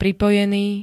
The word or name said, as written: pripojený